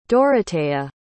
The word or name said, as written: Dorotea